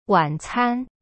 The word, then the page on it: 晚餐: wan-can